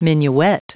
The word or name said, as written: minuet